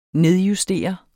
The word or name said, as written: nedjustere